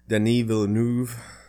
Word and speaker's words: Denis Villeneuve: so the next person on your list is Denis Villeneuve.